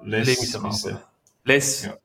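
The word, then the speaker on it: Les Misérables: Vom 2000, haben sie jetzt schön ausgesprochen? Le Miserables. – Le Miserables. Miserables. – Genau so heisst der Titel.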